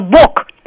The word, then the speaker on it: Бог: Я говорю Бог, у меня там звук Х совершенно точно.